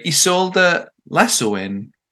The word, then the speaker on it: Isolde Lasoen: It's Something French by Isolde Lasoen.